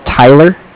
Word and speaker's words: Tyler: Okay, my name is Tyler, T-Y-L-E-R. I'm with Bank of Cardiff. It's spelled C-A-R-D-I-F-F.